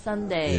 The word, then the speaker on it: sundae: Careful here, guys, because this is not sundae like the day. – Saturday sundae. – Saturday sundae, right? – This is... – Well, it's pronounced the same. – Spelling is different. – Spelling is different. – It's S-U-N-D-A-E.